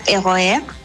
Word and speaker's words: RER: Si tú tomas un tren, los trenes son como metro tren, se llaman RER, que se pronuncia ROR.